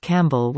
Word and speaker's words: Campbell: Then we moved to the Mahoning Valley and discovered the city of Campbell was pronounced like the animal camel.